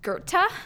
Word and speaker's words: Goethe: My husband told me that this word is pronounced Goethe. It's spelled G-O-E-T-H-E.